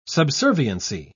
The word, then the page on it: subserviency: British English